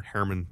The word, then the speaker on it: Herrmann: Herrmann, two syllables.